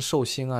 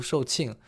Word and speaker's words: 售罄: 售罄啊，就是我我第一次看到这个字的话，也不太会读，呃，读成寿星啊什么之类的，读售罄。